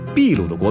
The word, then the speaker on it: 秘鲁: 因此，一直以来，秘鲁的国名都是秘鲁。据说啊，是当地一条河流的称呼。而在中文里，秘鲁这个发音的缘由呢，则是因为地名的音译，西班牙语中的 p 发的是 b 的音，所以秘鲁才叫秘鲁，而不是秘鲁。